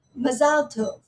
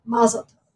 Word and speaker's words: mazal tov: So for example, so it's not only in the lexicon using different words, but it's also pronunciation where, for example, an Israeli might say mazal tov, so with the stress being one way, whereas, you know, an American Jew will say like, mazal tov.